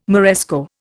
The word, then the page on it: Moresco: Indian